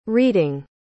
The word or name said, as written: reding